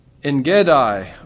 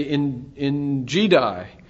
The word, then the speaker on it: En Gedi: There's the cave of Adullam in 1 Samuel 22, and then there's the cave of En Gedi, or the self-pronouncing in my Bible would have us say in Gedi, in 2 Samuel 24.